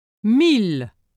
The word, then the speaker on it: mille: Be aware that sometimes ill is pronounced as il. Since there is no rule to guide you in these cases, you must memorize the pronunciation. Listen and repeat. – Mille.